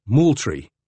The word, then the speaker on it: Moultrie: Fort Moultrie is pronounced Moultrie.